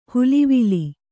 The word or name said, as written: ಹಳವಳ